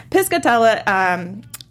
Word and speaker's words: Piscatella: But so Piper is on this trek to find Alex, and during this whole span of time, we find out that Piscatella. – Oh, I'm not saying it right. Sorry, yeah. My brain is on fire. Piscatella,